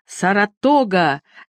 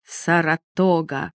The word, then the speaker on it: Саратога: Саратога!» Он смаковал звучное незнакомое слово, делая ударение на последнем слоге.